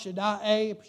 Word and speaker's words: Shaddai: That title, Shaddai, I believe it's pronounced Shaddai, indicates,